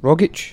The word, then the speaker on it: Rogic: Rogic. – That's how you say it. – Is that how you pronounce it? – Yeah.